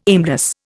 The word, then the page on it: aimless: Indian